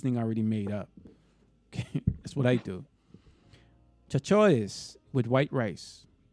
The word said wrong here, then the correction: chicharrones: Chicharrones. – Am I saying it right? Chachachones? – Chicharrones.